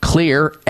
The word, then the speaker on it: Xlear: and Clear is spelled X-L-E-A-R.